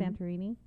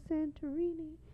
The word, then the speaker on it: Santorini: Santorini. How do you say it? – Yeah. Mm-hmm. – Santorini? – Yeah. – I don't practice Greece. – Santorini.